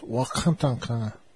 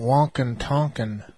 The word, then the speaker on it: Wakantanka: There's that word Wakantanka is actually pronounced Wakantanka. It's not Wakantanka.